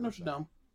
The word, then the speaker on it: Notre Dame: Notre Dame.